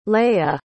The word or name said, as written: leigha